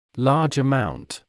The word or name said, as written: large amount